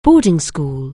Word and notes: boarding school: anglais

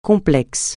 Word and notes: complexe: francais

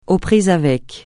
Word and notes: aux prises avec: francais

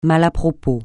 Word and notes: mal à propos: francais

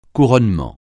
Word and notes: couronnement: French

